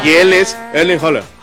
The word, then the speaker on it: Erling Haaland: Bueno, Alfredo González, comentarista de Fórmula Deportes, ¿cómo se pronuncia? – Holland. – Es con O. – Y es rápido, es Erling Haaland. – Haaland. – Así es su nombre.